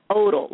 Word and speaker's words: Odal: It's Odal, like idle, but with an O.